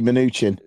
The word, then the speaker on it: Mnuchin: I always say Mnuchin. – Mnuchin, annoyingly, he has an M and an N in initial position, two consonants and no vowels. I find that very difficult to pronounce.